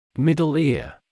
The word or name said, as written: middle ear